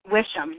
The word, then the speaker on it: Wishom: Wishom, yes.